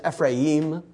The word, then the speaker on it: Ephraim: I'm going to continue to pronounce it Ephraim. It's Ephraim would be the more proper pronunciation, but the anglicized version is Ephraim, and we'll call him that.